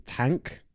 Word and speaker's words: Tangk: I'm guessing it's Tank. Yeah. But it's spelled weirdly, it's like T A N G K.